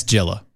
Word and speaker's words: Gila: Yeah, it's Gila. – No, I bet you it's enunciated Gila, like Gila Monster. That's how it's spelled.